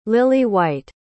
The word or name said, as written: lily-white